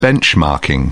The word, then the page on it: benchmarking: British English